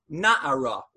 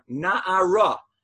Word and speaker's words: na'ara: it's spelled na'ar, it's pronounced na'ara, which means a young lady, except it's written without the hey, nun ayin resh, and it's pronounced the same, na'ara. You don't need the hey to get that phonetic pronunciation.